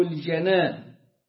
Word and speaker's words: الجنان: تصديق بالجنان، وتقولها هكذا بفتح الجيم، لأن كلمة الجنان جمع جنة ولها معنى آخر نوع من الجمع لكلمة جنون، جنون وجنان. تقول ده الجنان اللي بيحصل، ده الجنان. أو جنة تجمع على جنان وجنات. وهنا الجنان بفتح الجيم هو القلب، تصديق بالجنان وتلفظ باللسان وعمل بالأركان.